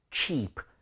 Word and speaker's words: cheap: 그래서 얘는 chips, 입 모양이 안 벌려져요. 근데 얘는 cheap, 살짝 벌려줘야 됩니다.